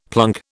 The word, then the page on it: plunk: British English